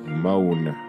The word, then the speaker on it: Moen: Moen. Is that how you say it? M-O-E-N. What the fuck does Moen? Moan. +1-800-BY-MOEN.